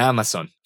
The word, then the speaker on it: Amazon: De Amazon, from Amazon. Cuidado con la pronunciación de la Z en inglés, que es z.